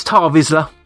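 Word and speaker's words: Tarre Vizsla: It's Tarr Vizsla. – Tarr Vizsla. – I'll give you that. Yeah. You knew the answer. – It was the pronunciation. It was an A with a line over it.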